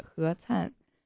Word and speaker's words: 何灿: 你决定不是第一个叫错我的姓的美国人，确实挺奇怪的，有些中文名字的发音和英文发音截然不同，比如我一个朋友的名字拼写是 C-A-N-H-E, 你觉得应该怎么念他的名字？ – Can he? – 哈哈，我就知道你会念错，正确的发音是何灿。